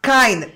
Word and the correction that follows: kein: pero se dice kind como amable en inglés. No, se escribe K-E-I-N. E-I es A-I. – Sin la D. – Sin la D, no, no, Cain. Ah, Cain. Como Caín y Abel.